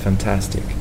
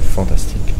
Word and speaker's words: fantastic: It's fantastic. L'accent tonique en anglais est sur la deuxième syllabe. Le français, il va souvent faire des erreurs de l'accent tonique en anglais parce que pour lui, c'est pas prononcé en français. Et il va avoir même tendance à le mettre sur le point, il va avoir tendance à mettre fantastique. Voilà.